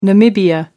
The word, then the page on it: Namibia: American English